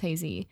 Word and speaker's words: Heize: It was actually a Dean interview, and they were talking to them about his work with her, and the interviewer pronounced it Haze, but put like an emphasis on the end. So I think it's Hazy. Mm. But I could be wrong.